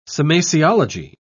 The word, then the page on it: semasiology: British English